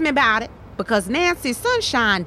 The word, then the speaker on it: Sunshine: See, look, most people don't know this, but it's not Sunshine. It's actually pronounced Sunshine. My grandfather was Japanese.